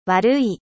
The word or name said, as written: mauvais